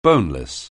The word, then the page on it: boneless: anglais